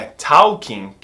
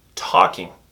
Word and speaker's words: talking: Preste atenção na pronúncia do talking. Não é talking, que nem talvez você venha falando. Esse L é mudo. Talking.